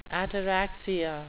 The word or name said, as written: ataraxia